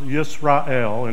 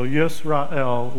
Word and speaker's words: Yisrael: The name Israel itself, pronounced Yisrael in Hebrew, can be taken to mean right with God, from two Hebrew words, yeshar, right or honest, and el, God. So Yisrael literally means right with God.